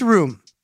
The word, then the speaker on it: rheum: Crusties, eye crust, eye boogers, eye sugar, sleepies, eye goop, and eye gunk are all slang for a substance technically known as rheum. But not like living room or bedroom. This rheum is spelled R-H-E-U-M, and it's G-R-O-S-S.